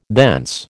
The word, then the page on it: thence: American English